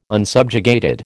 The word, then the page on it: unsubjugated: American English